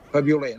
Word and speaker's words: fabioler: Fabioler, nosaltres deim fabioler, ho faré en sa pronúncia menorquina.